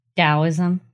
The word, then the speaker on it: Taoism: Taoism, spelled with a T, but okay, Taoism, for Western minds in the 1940s.